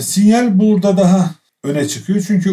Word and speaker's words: Signal: Öbür taraftan Telegram ve e, Signal, Sinyal diye okunuyor. Signal diye yazılıyor. Signal.